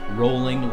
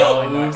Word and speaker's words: Rowling: One of my friends texted me and said that I say J.K. Rowling's name wrong. He said rolling like bowling. – Yeah. We all say really? Rallying.